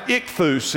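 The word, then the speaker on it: ichthus (ἰχθύς): Did you know it was accompanied by some Greek words that are pronounced ichthus is how you would say it in the Greek, and those words mean God's Son, Jesus Christ, God's Son and Savior.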